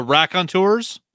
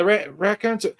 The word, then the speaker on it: Raconteurs: Number two is Carolina Drama by the Raycontours. I don't know how to say the word. You know the band, right? Raycontours. No. – The Raconteurs? – Yeah, Raconteurs.